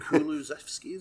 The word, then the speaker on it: Kulusevski: Kulusevski, is it? – Yeah, who Ronaldo speaks very highly of.